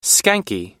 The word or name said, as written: skanky